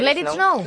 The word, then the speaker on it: Let It Snow: Let It Snow. ¿Lo he dicho bien? – Venga, pues Let It Snow. – Lo ha dicho bien.